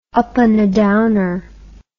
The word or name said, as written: upandadowner